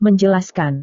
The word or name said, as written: menjelaskan